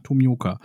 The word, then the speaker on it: Tomioka: Tomioka. Habe ich es richtig ausgesprochen? Ja, Tomioka.